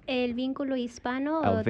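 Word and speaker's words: El Vínculo Hispano: Did I pronounce that right? – It's close. – All right. What is it? Give me the right. – Alvinculo Hispano, the Hispanic liaison.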